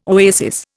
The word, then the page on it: oasis: Indian